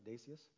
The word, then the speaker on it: Decius: And there was a terrible persecution unleashed again against the Christians by Emperor Decius, I think is how you would pronounce that, Decius.